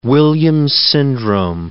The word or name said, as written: Williams syndrome